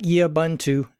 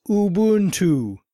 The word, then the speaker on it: Ubuntu: It's not Yubuntu, as I've heard you pronounce it. It's Ubuntu."